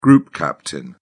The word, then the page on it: group captain: British English